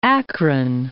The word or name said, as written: Akron